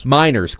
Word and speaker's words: Meinerz: I hope I'm pronouncing his last name correctly. Is it Quinn Meinerz? – Meinerz.